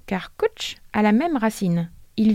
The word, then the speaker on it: cwtch: Il s'écrit C-W-T-C-H. – Attends, mais on le prononce comment ça? – Ça se dit coach.